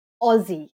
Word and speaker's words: Aussie: Is it Aussie or Aussie? Is it a Z sound or an S sound? Which is it? – Aussie.